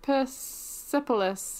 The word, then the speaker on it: Persepolis: I said Persepolis, yeah, because I pronounce it like Persephone.